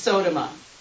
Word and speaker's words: Sodoma: Is that O pronounced like an O or an A, or is it Sodoma? – It's Sodoma.